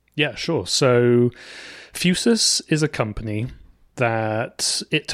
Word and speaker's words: Fusus: F-U-S-U-S. – That's how we've been pronouncing it, and I'm going to go with that because we mispronounce on the podcast all the time. So let's just go with Fusus.